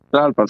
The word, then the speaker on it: Tlalpan: El domingo tenemos un meet and greet con Mascatesta en el Bebops en Tlalpan. ¿Tlalpan? Sí. – ¿Tlalpan? – Tlalpan, sí.